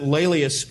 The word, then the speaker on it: Laelius: I'll spell it for you. Laelius is L-A-E-L-I-U-S. Laelius.